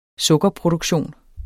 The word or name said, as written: sukkerproduktion